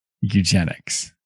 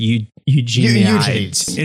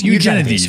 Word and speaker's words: Eugenides: Certainly, he's not someone who could listen to this podcast and be bothered by the fact that we're mispronouncing his last name is eugenics. – Okay. What is it actually? It's Eugenides. – It's Eugenides.